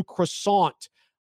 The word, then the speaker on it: croissant: I'm actually going to debut a new croissant dish. It's going to be like a pizza croissant dish that I'm going to be working on. So you got to pronounce it with no T.